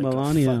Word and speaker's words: Melania: Oh, like Melania, fucking 13.